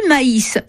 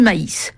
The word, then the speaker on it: maïs: Du maïs, ça s'écrit M-A-I avec un tréma et S. Sans le tréma, ça donne le mot mais, la conjonction de coordination. Avec le tréma, c'est du maïs.